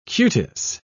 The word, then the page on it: cutis: British English